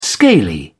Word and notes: scaly: British English

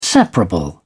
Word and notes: separable: British English

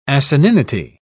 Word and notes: asininity: British English

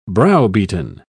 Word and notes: browbeaten: British English

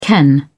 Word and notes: ken: British English

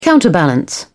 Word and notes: counterbalance: British English

